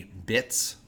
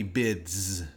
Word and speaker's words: Bitz: You cannot pronounce B-I-T-Z. It's either going to be bits. – Yeah. – Or it's going to be bids. B-I-D-Z. – Bit. – You're not going to be able to do it.